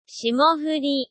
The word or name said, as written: shimofuri